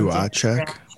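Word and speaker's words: Duhatschek: But anyway, that doesn't deter the good folks like Craig Custins and good old, I always mess up his name, Duhatchek. I didn't say that right, did I? – Do I check? – Yeah.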